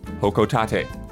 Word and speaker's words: Hokotate: And most importantly, I was not sure how to pronounce that planet that Olimar comes from in the Pikmin series, but I looked up an old trailer from a previous game, and Isla was correct. The pronunciation is indeed Hokotate.